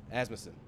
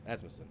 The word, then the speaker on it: Asmussen: Steve Asmussen. – Asmussen. – Asmussen. – Yeah. – Asmussen. That's how you say it.